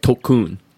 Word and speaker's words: Tulkun: Apparently, like, if you called the I gotta say it right, Tulkun. That's how you, it's T-U-L-K-U-N, Tulkun.